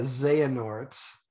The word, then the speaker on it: Xehanort: He replaced Nimoy as the voice of Zenart. I can't even know. I don't know how to pronounce the fucking guy's name. The villain of Kingdom Hearts. – Xehanort. – Yeah, Xehanort. That's how that's pronounced.